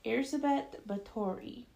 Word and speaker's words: Erzsébet Báthory: Elizabeth Bathory, or Erzsébet Báthory, as it's pronounced in Hungarian, was born in 1560 into aristocracy.